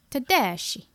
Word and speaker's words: Tedeschi: Susan Tedeschi. – Yeah, it sounds very Italian. It makes me want a meatball. – A spicy meatball. – Tedeschi.